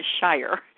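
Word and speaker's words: Shirer: Priscilla Shire in her book, Life Interrupted, Priscilla Shire, got to say that name right, Priscilla Shire, is the way you pronounce her last name, has written Life Interrupted about Jonah's journey and ours through interruptions.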